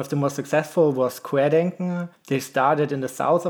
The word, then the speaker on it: Querdenken: How do you pronounce that? – Querdenken. – Okay. Yeah. So the leader of apparently Querdenken 711, Michael Bauweg, he openly used a QAnon slogan in English during a protest in August of 2020.